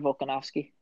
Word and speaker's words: Volkanovski: Alexander Volkanovski. – Volkanovski. I cannot pronounce him, say his name. – No, you're good. It's complicated.